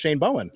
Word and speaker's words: Bowen: Oh, we didn't even talk about Shane Bowen.